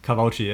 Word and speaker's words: Kawauchi: Der japanische Kawauchi, äh, oder? – Dauerbrenner. Ja, du hast, du hast richtig ausgesprochen. Richtig. Kawauchi, ja ähm, durfte nur oder ist warum auch immer nicht im Elitefeld gestartet, sondern mit äh, ja, der ersten Welle der normalen Marathonläufer.